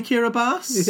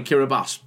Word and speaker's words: Kiribati: Can we buy Kiribati? – Yeah. – Oh, sorry, Kiribati. That's how it's pronounced.